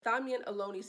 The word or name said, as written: Thamien Ohlone